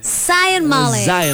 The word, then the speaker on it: Zayn Malik: Hay boletos para Zayn Malik, sábado 20 de junio, Zayn. Estadio GNP Seguros. ¿Cómo se dice? – Zayn Malik.